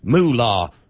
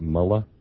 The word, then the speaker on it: mullah: The shame that he subjects this country to, him with his stupid little moolah comments. He knows it's mullah.